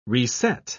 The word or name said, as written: reset